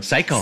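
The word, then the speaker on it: saikō: Ja, so wie man auf Englisch Psycho aussprechen wird, aber es ist S-A-I-K-O mit dem Strich drüber.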